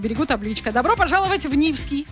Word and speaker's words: Нивский: У нас в России, в Мурманской области, в удивительном городе Нивский, с ударением на первый слог, чтобы сразу почувствовать мощь и основательность этого места.